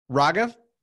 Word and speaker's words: Raghav: Raghav.